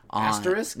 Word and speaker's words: asterisk: Actress. I can never pronounce it. – Asterisk? – Yes. Thank you.